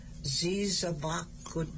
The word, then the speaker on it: zizabakood: Uh, zizin bakwad. – Z. Sugar is zizabakud. – Oh, zizabakud. Zizabakud. – Z I Z A B A K O O D. – So. Zizabakud is sugar.